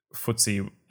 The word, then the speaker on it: FTSE: And if you actually Google FTSE and how to spell that is FTSE, well, that's how it's pronounced, FTSE 100.